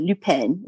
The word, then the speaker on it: Lupin: I think you pronounce it Lupin. It's French.